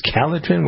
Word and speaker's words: Calotren: And as we're talking about losing weight, how about we spend a moment talking about Calotrin, which is it's spelled C-A-L-O-T-R-E-N. I always get comments from people. How do you spell this? It's Calotrin. What is the C-A-L-O-T-R-E-N?